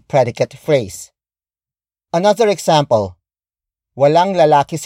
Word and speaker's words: lalaki: And that is lalaki. Lalaki. Lalaki. Lalaki is spelled as L-A-L-A-K-I.